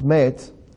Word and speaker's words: met: Now, the second word, mem tav, pronounced met, that is the Hebrew word for death.